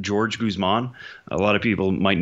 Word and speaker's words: Jorge Guzman: You know, I'll be the first to admit I'm not always great with pronunciations, but the man previously we believed to be Jorge Guzman wants to be called George Guzman. – Okay. – That's how it's said.